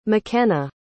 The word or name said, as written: mckenna